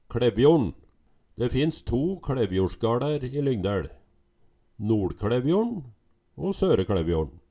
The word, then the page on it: KLevjorn, NoLKLevjorn, Søre Klevjorn: Numedalsmål